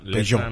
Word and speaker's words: Peugeot: Es que la última Peugeot.